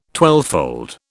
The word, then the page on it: twelvefold: British English